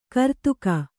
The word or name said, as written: ಗರ್ದುಗ